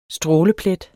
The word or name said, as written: stråleplet